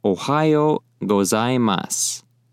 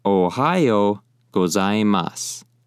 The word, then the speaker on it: Ohayo gozaimasu: As long as it's in the morning time, you would say, Ohayo gozaimasu. Once again, Ohayo gozaimasu.